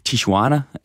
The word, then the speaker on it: Tijuana: Og derfor så skal de bo i den mexikanske grænseby Tijuana, hvis udtalen er simpelthen korrekt. – Det er den.